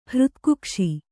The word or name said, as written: ಹೃತ್ಕುಕ್ಷಿ